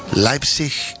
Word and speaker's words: Leipzig: Y después pongan al lado Leipzig Big Band. Se escribe L-E-I, se pronuncia Leipzig, pero se escribe Leipzig. L-E-I-P-Z-I-G.